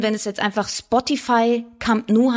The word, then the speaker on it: Camp Nou: Und es hat sich dann halt, ja, es war eine leichte Revolte sozusagen, dass es weiterhin einfach katalanisch ausgesprochen wurde und Camp Nou heißt.